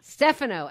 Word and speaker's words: Stefano: Stefano, excellent.